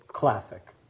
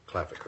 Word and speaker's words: Klaphake: Klaphake is actually, the PH is phonetically together like an F. I represent Cecil Klaphake in these consolidated appeals. – And how do you pronounce that last name? – Klaphake.